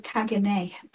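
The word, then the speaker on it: caganer: A cagina? A cagina? – Oh, cagine.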